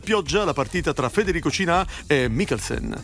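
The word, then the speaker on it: Cinà: Alcuni fanno la battuta, alcuni tolgono l'accento e lo chiamano Cina, però è un modo di farlo. – Cina. – Sì, sì, è Cina. Comunque, Federico ha perso il primo set 6-4, pur giocando anche abbastanza bene.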